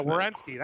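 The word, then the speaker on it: Werenski: Savard, Seth Jones, Wazenski, or I don't know how you say it. Yeah, Wierenski.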